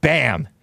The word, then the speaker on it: BAMN: There's a communist organization called BAM. BAMN. It's spelled kind of like the cuss word damn, but it's called BAM. And it's BAMN.